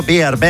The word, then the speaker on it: Beard: Con la cerveza y el oso, Beard. – Ahí está. – Beard.